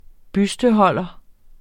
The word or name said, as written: busteholder